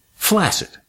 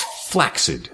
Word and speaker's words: flaccid: and I'd used the word that is spelled F-L-A-C-C-I-D, and I pronounced it flaccid. And my mother just froze, and she looked at me and said, "That's flaccid, dear." And I said, "Get out of here. My English professor says flaccid." And she says, "Well, your English professor is wrong."